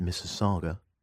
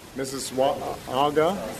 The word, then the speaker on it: Mississauga: Mississauga is a dwelling near here in Toronto, part of the extended GTA, Greater Toronto Area, and it's pronounced Mississauga, unless you're Obama, who says, this is always tough. Mississauga. Mississauga.